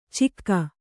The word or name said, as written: ಚಿಕ್ಕ